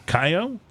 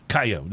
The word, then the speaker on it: Kaiyo: Kayo? That's how I pronounce it. – K-A-I-Y-O. Kayo. Right? Because it's got a K in there? No. Kayo. There's no second K.